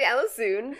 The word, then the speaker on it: Allissoon: It's actually Allissoon, but yes.